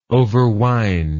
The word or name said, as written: overwind